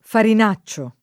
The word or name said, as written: farinaccio